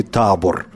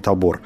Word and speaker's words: Табор: Ну, поскольку речь идет о Чехии, то я не уверен с ударением, но, видимо, если речь идет о Чехии, то все-таки Табор должна она называться, а не Табор.